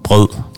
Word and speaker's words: grød: Usually, if you want to test people's pronunciation in Danish, you have to say the name of a dessert called rød grød med fløde, because it's all these like ø and d sounds, like there's five of them in like one small.